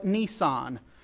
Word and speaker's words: Nisan: So the first month of the Hebrew calendar is called Nisan, spelled like a Nisan with one less S, N-I-S-A-N.